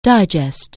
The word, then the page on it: digest: anglais